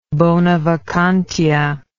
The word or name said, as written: bonavacantia